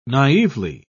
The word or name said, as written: naively